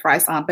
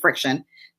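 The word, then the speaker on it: FriXion: And you can only use, and I've been pronouncing this so wrong, I've been calling it Frisson, but it's actually Friction.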